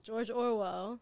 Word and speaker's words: George Orwell: George Orwell.